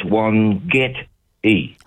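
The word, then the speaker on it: Wangetti: The first three letters, W-O-N, won, and then you emphasise the get, so it's one get e.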